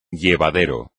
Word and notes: llevadero: Spanish

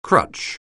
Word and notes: crutch: anglais

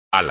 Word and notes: ala: espagnol

